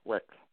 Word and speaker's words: Wecht: Kennedy, Robert Kennedy, JonBenet Ramsey, Dr. Cyril, is it Wetch? Is that how I pronounce it? – Cyril Wetch. – Perfect, perfect.